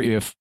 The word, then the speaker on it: Aye: His name is spelled A Y E.